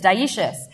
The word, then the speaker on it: dioecious: It's dioecious. But it's spelled really interestingly, dioecious, as opposed to monoecious.